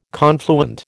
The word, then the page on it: confluent: American English